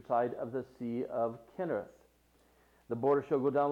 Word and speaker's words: Chinnereth: And it was called, and it looks like Chinnereth, but it is pronounced Kinnereth, and it means harp.